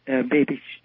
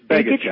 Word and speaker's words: Begich: Anyway, there's an electric field that extends out from the Earth, as I'm sure Dr. Begich knows. Am I pronouncing the name right? – It's Begich, actually.